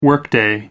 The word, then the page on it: workday: American English